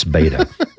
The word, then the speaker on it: Beto: I like to call him Beto because it's Beto. And besides, Beto rhymes with mosquito, and I like to call him that.